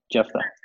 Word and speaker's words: Jephthah: Yep, Jephthah.